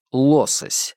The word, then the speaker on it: лосось: Ученые-рыбоведы произносят «лосось» с ударением на первый слог, подчеркивая свое отличие от обывателя, которого лосось интересует только как еда.